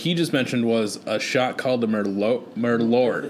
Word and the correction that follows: Malort: It's like Merlot, and then but it's like pronounced weird. – Yeah, it's Malort, and it's like super boozy.